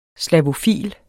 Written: slavofil

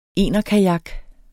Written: enerkajak

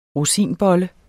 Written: ROSINBOLLE

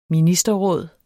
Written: ministerråd

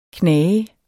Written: knage

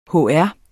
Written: hr.

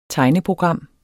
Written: tegneprogram

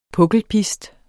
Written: pukkelpist

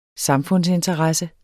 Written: samfundsinteresse

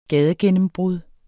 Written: gadegennembrud

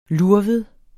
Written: lurvet

